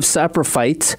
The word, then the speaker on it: Saprophyte: It's called Cult of Saprophyte. I believe I pronounced that correctly. – You did.